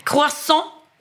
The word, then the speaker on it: croissant: Nu står det att det är fel här, för man ska uttala det croissant. Croissant. – Ja, men det är det som är grejen. Vi uttalar fel. Så croissant är alltså fel. – Det ska inte vara NG på slutet.